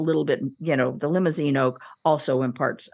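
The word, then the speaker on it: Limousin: So you often hear about Limousine oak, and it's exactly how it sounds, except like the car, except without an E at the end, but it's pronounced in the same way.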